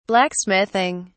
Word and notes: blacksmithing: British English

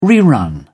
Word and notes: rerun: British English

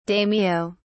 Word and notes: daimyo: British English

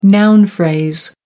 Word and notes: noun phrase: American English

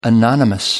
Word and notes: anonymous: American English

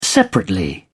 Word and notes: separately: British English

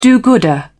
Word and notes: do-gooder: British English

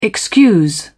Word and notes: excuse: British English

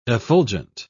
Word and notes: effulgent: British English

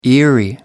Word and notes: eerie: American English

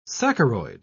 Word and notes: saccharoid: British English

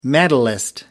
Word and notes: medalist: American English

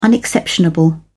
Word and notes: unexceptionable: British English

